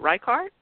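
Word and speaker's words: Rikaart: Eric Martzoff, Greg, is it Reichardt? Is that how you pronounce his last name? Right. – Yep.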